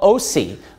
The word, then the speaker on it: Osee: Hosea, you know, the book of Hosea of the Old Testament, he's called Osi. It's spelled O-S-E-E in the New Testament.